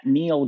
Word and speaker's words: Neil: I am at that Neil guy, and my name is spelled N-E-I-L.